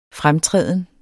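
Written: FREMTRÆDEN

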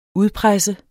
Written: udpresse

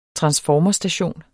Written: transformerstation

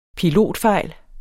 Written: pilotfejl

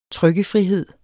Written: trykkefrihed

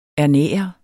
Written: ernære

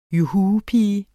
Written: juhupige

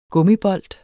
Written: gummibold

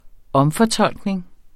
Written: omfortolkning

